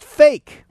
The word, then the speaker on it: PHEIC: PHEIC, pronounced fake.